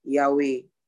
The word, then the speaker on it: Yahweh: Jehovah is a Latinian and Anglicized version of the Hebrew tetragrammaton Yahweh.